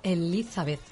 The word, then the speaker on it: Elizabeth: Elizabeth.